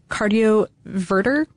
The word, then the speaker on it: cardioverter: There are also related implantable cardioverter. Cardioverter, did I say that right? – Yep.